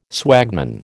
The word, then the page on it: swagman: American English